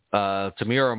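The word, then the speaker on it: Temuera: Tamura Morrison. I could never pronounce his first name correct. – You got it.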